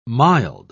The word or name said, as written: mild